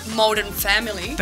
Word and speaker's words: Modern Family: ¿No lo he pronunciado bien? – Modern Family. – Perfecto.